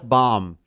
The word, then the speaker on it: Bohm: The first one is about Philadelphia Phillies third baseman Alec Bohm. I believe I'm pronouncing his last name correct. Yeah, you are.